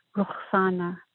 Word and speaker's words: Ruxana: It's Persian, so the correct pronunciation is Ruxana, and it means dawn or new beginning.